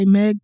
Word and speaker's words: Meg: and it looks kind of exciting and also rhymes with Peg. – Okay, it does say Meg too, the Trench.